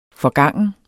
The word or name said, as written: forgangen